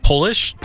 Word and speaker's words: Polish: Is it Polish? – Polish and polish. – Oh, well done. – Nice job. – It's the word Polish is pronounced differently when it's lowercase.